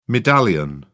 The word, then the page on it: medallion: British English